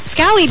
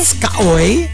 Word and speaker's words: Skaoi: And Sebedee says, happy birthday to my niece, Skowoy. It's her third birthday. Because look, S-K-A-O-I. How do you pronounce that? – Scowie, baby. – Scowie. Happy birthday.